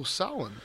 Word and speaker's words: Samhain: I found out that we've been saying it wrong. It's not Samhain. That's an American thing. – Samhain? What is it? – I'm going to have to Google it because I already forgot. Samhain. Samhain. – Oh, Samhain. – Because M and H make a W sound. Real life pagans would get all shitty about it. Like, you're saying it wrong because it's Gaelic pronunciation.